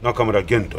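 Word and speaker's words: 中村玄: 中村源、はい、小里編に元元町の元と書いて中村玄と読むんですよね。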